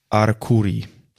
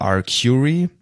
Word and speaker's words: Arcuri: Ich sag dir, mein Lieblings-Stand-Up-Comedian ist Jeff Arcuri. Oder Arcuri, je nachdem, ob man das Englisch oder Italienisch aussprechen möchte.